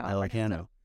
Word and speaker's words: Pellicano: And so I asked him when I first got hired, but how would your dad have pronounced it? And he said, Well, my dad would have pronounced it Pellicano. – There you go. Done. that's how Pellicano. What you don't want to do is be a pelicant.